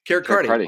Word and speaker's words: Carricarte: Carricarte.